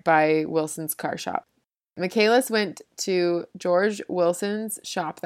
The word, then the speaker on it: Michaelis: I looked it up, and I'm pretty sure it's Michaelis, but it's spelled like Michael with an IS on the end, Michaelis. But I think you pronounce it Michaelis, so that's how I'm going to say it.